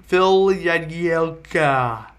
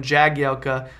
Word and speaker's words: Jagielka: Phil Jagielka, which for the majority of the time I've known that guy, I've called him Jagielka, but apparently his name is pronounced with a yeah sound.